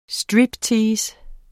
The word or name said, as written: striptease